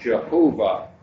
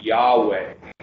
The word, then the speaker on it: Yahweh: traditionally, or in older times, pronounced Jehovah, but usually now, more recent scholars pronounce it Yahweh.